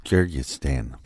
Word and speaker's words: Kyrgyzstan: Don't make me say that again because I said it wrong the first time. – Kyrgyzstan.